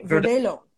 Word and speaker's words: Verdelho: Mm, yes of of verdeo verdage verde, how do you say Verde, Verdelho Verdelho, Verdelho.